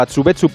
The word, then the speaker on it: Atsubetsu: Y a las 5 aproximadamente harán el último entrenamiento en el estadio Atsubetsu Park de Sapporo, antes del partido contra Egipto de este jueves a las 9:30. – Muy bien pronunciado el nombre del estadio.